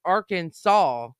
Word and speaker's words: Arkansas: Arkansardians to Title I of 2010, Arkansas's code, the state's name should be pronounced in three syllables with the final S silent, the A in each syllable with the Italian sound and the accent on the first last syllable. The pronunciation with the accent on the second syllable with the sound of A in man and the sound of the terminal S is innovation to be disregarded.